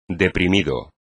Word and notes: deprimido: espagnol

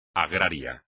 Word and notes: agrario: espagnol

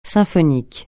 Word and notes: symphonique: francais

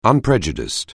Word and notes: unprejudiced: anglais

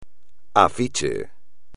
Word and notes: afiche: espagnol

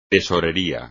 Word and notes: tesorería: espagnol

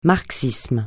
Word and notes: marxisme: francais

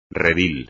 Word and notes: redil: espagnol

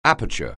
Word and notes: aperture: anglais

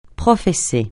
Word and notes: professer: francais

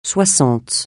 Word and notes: soixante: francais

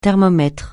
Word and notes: thermomètre: francais

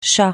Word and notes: chas: français

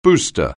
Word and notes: booster: anglais

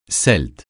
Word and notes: Celte: francais

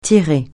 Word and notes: tirer: francais

